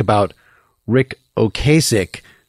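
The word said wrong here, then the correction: Ocasek: whose name is actually Ocasic, but he doesn't care how you pronounce it.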